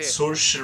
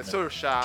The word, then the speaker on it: Saoirse: Cersei Ronan, adesso non so come si pronunci, in Piccole Donne sicuramente... – Saoirse eh, Ronan. Eh, Saoirse, Saoirse Ronan.